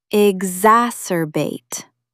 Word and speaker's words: exacerbate: Exacerbate. Ig zas er bait. Meaning, to make a bad problem even worse, harsher, or more intense. It shares the Latin root acer, sharp, with acrimonious. Scratching a mosquito bite will only exacerbate the itch. Stress the second syllable. Exacerbate. Say it out loud.